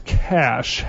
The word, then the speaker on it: cache: I've heard it referred to as cache. I think it is correctly pronounced cash, however.